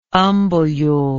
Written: umbellule

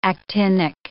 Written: actinic

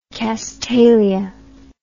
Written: castalia

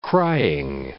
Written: crying

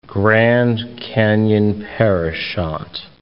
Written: Grand Canyon-Parashant National Monument

